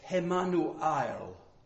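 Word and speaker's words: Emmanuel (Ἐμμανουήλ): Actually, if you want to pronounce it Greek, it's Emmanuel. The emphasis, or the emphasis, is on the last syllable.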